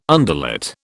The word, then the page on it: underlet: British English